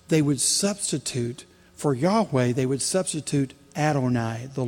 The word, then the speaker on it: Yahweh: We might pronounce it Yahweh, or in the Middle Ages, we used to say Jehovah.